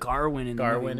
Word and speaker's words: Gawain: Gawain, I think is how they pronounce it. Garwin in the movie. – Yeah, something like that.